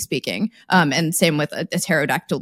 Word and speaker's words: pterodactyl: The silent P in pterodactyl, by the way, wasn't silent in Greek, but it is silent in English due to what we call phonotactics, or yeah, like the rules of pronunciation. English doesn't like P's and T's next to each other at the beginning of a word, so we just pretend it doesn't exist.